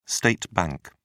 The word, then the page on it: state bank: British English